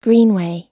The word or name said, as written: greenway